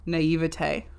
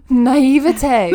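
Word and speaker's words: naïveté: Naivete. This is like you're naive. – Naivete.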